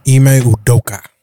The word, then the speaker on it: Ime Udoka: Ime Udoka.